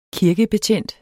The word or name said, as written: kirkebetjent